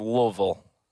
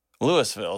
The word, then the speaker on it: Louisville: This guy was from Louisville. I am from Nashville. – So ridiculous. Louisville is how it's spelled. The Louisville is how it's pronounced.